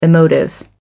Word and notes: emotive: American English

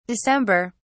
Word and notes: december: British English

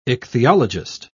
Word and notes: ichthyologist: British English

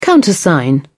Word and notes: countersign: British English

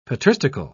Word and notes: patristical: British English

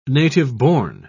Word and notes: native-born: British English

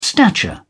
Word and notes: stature: British English

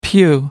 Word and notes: pew: American English

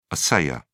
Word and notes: assayer: British English

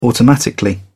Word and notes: automatically: British English

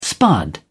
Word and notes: spud: British English

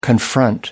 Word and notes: confront: American English

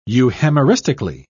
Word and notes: euhemeristically: British English

